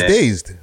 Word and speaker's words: Dazed: No, it's Dazed. Magazine, British magazine Dazed. I'll pull it up on the screen here. – D-A-Z-E-D? – Mm-hmm.